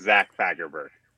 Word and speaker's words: Zach Fagerberg: My full name is Zach Fagerberg.